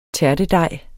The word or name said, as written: tærtedej